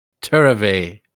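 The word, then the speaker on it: tirrivee: Turvey. That's how you say it.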